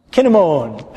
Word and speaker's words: kinnamon: And you know how it's pronounced in Hebrew? Kinnamon. Everybody say kinnamon. Yeah, we get our English word cinnamon from the Hebrew word kinnamon. So, you can remember that.